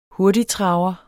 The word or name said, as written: hurtigtraver